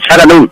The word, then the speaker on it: Chattanooga: Bueno, se, pronuncia Chattanooga en español, pero en inglés es Charanú.